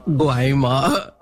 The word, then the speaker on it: Guayama: I think I need to write that, how I can, you know, spell it, because it doesn't look like Guayama on my script. It is like the spelling tells me a totally a different pronunciation.